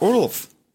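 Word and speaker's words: Orlov: в английском языке произносит Орлов, Орлов, угу. – не угу. Орлов, и, соответственно, ну, э, последняя «в» в английском языке, вот это, она теряется, она не произносится, и по-русски ее тоже в озвучке, его зовут Орла.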